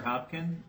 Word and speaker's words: Hopkin: Walter Hopkin, H-O-P-K-I-N.